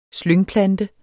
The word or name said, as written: slyngplante